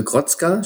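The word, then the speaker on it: Grocka: Deine Eltern stammen aus äh, Grotzka, spricht man das so richtig aus? – Ja.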